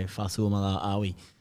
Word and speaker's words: Fa'asuamaleaui: I've got a decision to make out of AJ Brimson or Tino Fa'asuamaleaui.